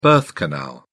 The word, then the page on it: birth canal: British English